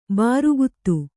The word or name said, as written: ಬಾರುಗುತ್ತು